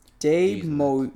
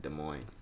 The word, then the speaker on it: Des Moines: Des Moines? Oh, yeah, it probably is pronounced like that. Des Moines. Des Moines, Iowa. You've never heard nobody say that. – I've never or say it the correct way like that.